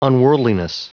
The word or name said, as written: unworldliness